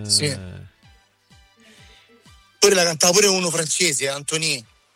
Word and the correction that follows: Antoine: Antoine, Forse Antoine. – scusa. Che pronuncia che c'ho? Antoine, sì. – Antoine, non Antonio.